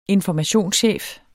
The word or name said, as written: informationschef